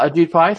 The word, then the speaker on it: Ajit Pai: Ajit Pai, thank you.